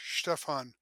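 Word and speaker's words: Štefan: It's only you got it right, except for it's Stefan, S-H, Stefan, Samuel Stefan Osusky.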